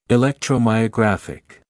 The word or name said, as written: electromyographic